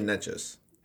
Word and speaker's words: Nečas: The Carolina announcers say Nečas. – I think it's Nečas, yeah.